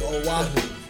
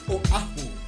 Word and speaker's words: Oahu: A lot of people say Oahu. It's actually Oahu. – Oahu. – Yeah. – Oahu.